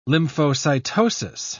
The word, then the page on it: lymphocytosis: British English